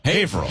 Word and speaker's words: Haverhill: H-A-V-E-R. – H-I-L-L.